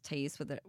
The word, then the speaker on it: Thais: The H is silent. So it's like Megan. Like, you don't pronounce Megan differently. There's an H in there. It's the same in Portuguese. – Oh, okay. – It's just Thais, whether there's an H or not.